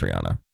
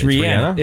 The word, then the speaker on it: Rihanna: I always knew it was Rihanna. – It's not. It's Rihanna. Rihanna.